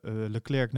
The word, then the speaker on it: Leclerc: Nou, vervolgens zien we daarna weer eh, de race in Singapore waar eh, het moment komt dat eh, eh, Leclerc naar binnen wordt gehaald.